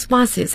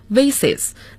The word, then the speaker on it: vase: They are glass vases and so on, 都是玻璃花瓶还有诸如此类的东西。那么刚才周老师的读音是 vases, 那么还有一个发音是 vase, 一个是 vase, 一个是 vase, 英音 vase, 美音 vase。